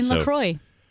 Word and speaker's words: LaCroix: It's LaCroix.